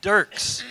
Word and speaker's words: Dirckx: It is D-I-R-C-K-X. Now, how do you say this? So all summer when we were interacting in my mind, I was saying Dirckx. And so the first thing I did was ask her, how do you say this? And she said Dirckx.